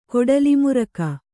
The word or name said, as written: ಕೊಡಲಿಮುರಕ